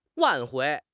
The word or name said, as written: 挽回